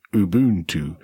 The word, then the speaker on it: Ubuntu: So I just tweeted from the Ubuntu account the pronunciation of the word Ubuntu, which is, you know, Ubuntu, the official pronunciation.